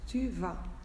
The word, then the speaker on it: tu vas: Eh, bueno, je vais, solo voy a deciros el verbo, V-A-I-S, tu vas, es exactamente igual que en español. Se escribe V-A-S, pero la pronunciación no es tu vas, es tu vas. Sin pronunciar la S final, la U es diferente y la V también un poco más distintiva de la B.